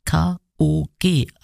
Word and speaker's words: K.O.G.: Jetzt habe ich ein Stück für euch, das heißt Running, eine energiegeladene Kollaborationssingle der britischen Festivalband Cut Capers und des Künstlers K.O.G., also die Buchstaben deutsch ausgesprochen.